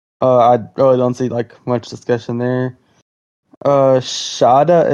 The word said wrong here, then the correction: Shota: and Manga? Is that how you say it? – Shota.